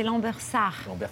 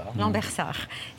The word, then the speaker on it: Lambersart: Oui, et j'écorche aussi le nom de votre ville, puisque c'est Lambersart. – Lambersart.